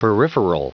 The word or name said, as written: peripheral